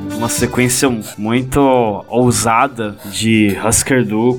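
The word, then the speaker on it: Hüsker Dü: Então, calma aí, que na verdade a pronúncia é Husker Du.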